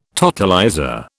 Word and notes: totalizer: British English

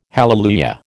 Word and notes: hallelujah: American English

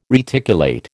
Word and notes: reticulate: American English

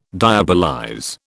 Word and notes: diabolize: British English

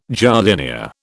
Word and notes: jardinière: British English

